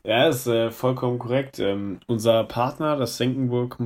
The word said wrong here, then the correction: Senckenberg: Museum, Senckenburg, ich kann nicht mehr unseren Partner richtig aussprechen, äh, das Senckenberg Museum in äh, Frankfurt ähm, hat uns, ähm, ich sag mal, eingeladen.